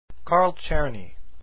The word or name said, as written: czerny